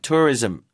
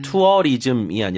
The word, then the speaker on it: tourism: educational, 주거 환경, housing, welfare, 그다음에 tourism support. 관광 이거 발음 도와드릴게요. – tourism. – tourism. – tourism. – 투어리즘이 아니라 tour. tourism. 다시 한번요.